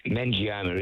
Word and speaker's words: meningioma: and it's called a meningioma. Is that what, am I saying that right? Yeah, I just want to know your thoughts on that sort of brain tumour. – Well, meningioma is the correct.